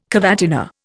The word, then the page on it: cavatina: Indian